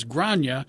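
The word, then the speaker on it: Gráinne: First name is spelled G-R-A-I-N-N-E, and I had to look it up. I wasn't sure, and it says the pronunciation is Grania, although they say there are two different pronunciations, one for Northern Ireland and one for Southern.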